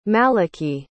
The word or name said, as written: malachy